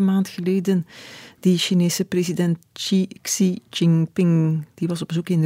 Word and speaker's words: Xi Jinping: Xi Jinping. – Ja. – Is dat het goede, de goede uitspraak? Zo Mandarijns, ongeveer, ja.